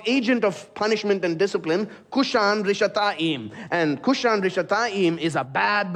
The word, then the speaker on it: Cushan-Rishathaim: In Hebrew, Mesopotamia there is the longer name is given, which is Aram Naharaim, which rhymes with his name, Cushan-Rishathaim. So even as you hear his name being proclaimed, you should feel the sense of, this is ominous. Cushan-Rishathaim, the man from Aram Naharaim.